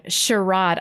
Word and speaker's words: charade: uh, bing bong charade. I know people say charade. The British say charade though.